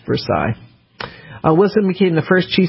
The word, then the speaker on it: Versailles: And so I said, Versailles. And he said, it's Versailles.